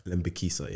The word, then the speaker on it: Lembikisa: Lembikisa, yeah.